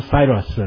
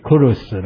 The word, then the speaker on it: Kurus: 拢有讲到波斯帝国元年吼，第一年吼，古列吼，Kurus 啊，当然这个英语吼，讲 Cyrus 啦，啊，有听者这发音也发了较未准，正确的发音是 Kurus 啦，吼，就是这个古列吼，所以这个四十五章第一节讲我要花所抹油的古列，这是要花上帝头一遍吼设立比赛啊，唔是代表的何意哦，代。